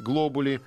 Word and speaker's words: глобули: Значит, глобули, там у них обычно ударение на... Первый слог. На первый слог падает, да.